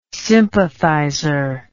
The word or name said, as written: sympathizer